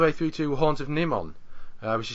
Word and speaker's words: Nimon: I mean, I think Ill of the Dead, but if you take Horns of Nimon, if I can just correct your pronunciation there, if you take the Horns of Nimon, you have that sort of farting noise that you get on the horn.